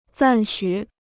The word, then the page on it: 赞许: zàn xǔ